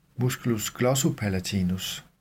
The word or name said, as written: m. glossopalatinus